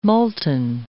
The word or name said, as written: molten